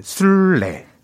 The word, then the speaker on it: 순례: 네, 순례. 그러니까 순례하다, 뭐 순례길 할때그 순례주택입니다. 사람 네. 이름이에요, 근데 그죠? 네, 이게 쓰면 확 들어오는데 네. 발음이 또 쉽지가 않네요. 니은이에 순례.